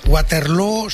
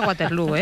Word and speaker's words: Waterloo: Pero en Abba se decía Waterloo, ¿eh? – Okay. Abba sí, pero no es el referente lingüístico de los flamencos, ¿eh? – Pues no lo es, desde luego, pero ha hecho mucho daño en la pronunciación de Waterloo, que se pronuncia igual que Feijó.